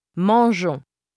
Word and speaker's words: mangeons: Insert the letter e after the g. – Mangeons. – This E is silent.